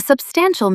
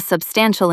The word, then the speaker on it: substantial: A substantial meal fills you up. A substantial improvement changes the game. Stress the middle syllable. Substantial. Say it aloud. Substantial.